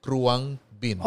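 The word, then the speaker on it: Khruangbin: There, it's a Thai word. K-H-R-U-A. – Talaga N-G. Krungbin. – Okay, but it's pronounced. – It's, Krungbin.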